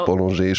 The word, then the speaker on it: Bollinger: Und zwar egal, ob da Veuve Clicquot draufsteht oder Tétanger oder Bollinger. Genau. – Bollinger spricht man es tatsächlich aus.